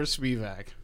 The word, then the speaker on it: Spivak: Am I saying this right? Spivak or Spivak? – It's Spivak.